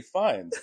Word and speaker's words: Fiennes: Ralph Fiennes, who we learned how to say his name correctly. – His name is not Ralph Fiennes. – No, it is not. While it looks exactly like Ralph Fiennes or Fiennes, it is Ralph Fiennes, as we learned.